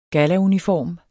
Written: gallauniform

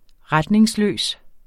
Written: retningsløs

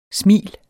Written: smil

